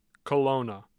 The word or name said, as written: colona